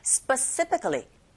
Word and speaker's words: specifically: 그래서 여기서는 people specifically pick 이렇게 강세를 주시면 되는데 specifically 발음하실 때 이 sp 먼저 sp 이렇게 발음해 보세요. sp 그리고 sp specifically 이렇게 발음하시면 됩니다. 좀 어려우신데요. 나눠서 발음한다고 생각하실 때 강세는 뒤에 간다는 거 specifically. Specifically 이렇게 하시면 돼요.